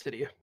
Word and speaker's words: Cydia: They're getting sued by, how do you say it? Cydia? – Yeah, it's Cydia.